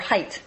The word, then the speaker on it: Haidt: Lukianoff and Haidt do not minimize, or Haidt, that's how you pronounce his name, H-A-I-D-T. I want to get it right.